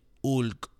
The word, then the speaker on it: Hulk: Me estoy convirtiendo en el Hulk. – Yeah. – Right, because we don't pronounce the H, right? – Right, Hulk. No, Hulk.